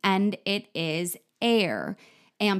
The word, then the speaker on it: Aire: People were thinking, because it's spelled A-I-R-E. People were saying, how do you pronounce it? And it could be like airy, whatever. And she did say, no, it is air, but it is spelled A-I-R-E. So there's a lot of controversy around this.